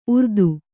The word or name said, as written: ಉರ್ದು